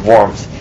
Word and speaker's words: Worms: You spell it Worms, right? But in Germany you pronounce it Worms.